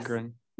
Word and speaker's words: Agron: I mean, you know, Diane Agron. Did I say that correctly? – Agron. Yeah. – Yes. – Agron. Yeah, it's Agron, not art. Yeah, you got it now.